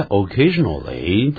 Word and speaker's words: occasionally: 不过在 occasionally, 它的音标里头它念成 occasionally, 少了一个 L 的这个符号在里边 ,lally, 但是呢实际上呢一般还是念 occasionally 比较多哦。